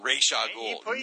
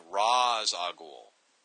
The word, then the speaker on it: Ra's al Ghul: It's not Ra's al Ghul. – You said it. – Yeah. Nowhere in the Arabic language is Aish a noise. Ra's. It's pronounced Ra's al Ghul.